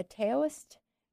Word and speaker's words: Taoist: Now, you are a Qigong teacher, master, and then a Taoist, is that how you say it? – Yes.